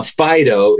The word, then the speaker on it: phyto: Okay, now Fido is not your dog that you used to sing about. And it's spelled P-H-Y-T-O.